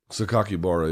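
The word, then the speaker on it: Sakakibara: Is that how you say it? Saiki Kabara? – Sakaki Bara, yeah.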